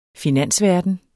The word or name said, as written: finansverden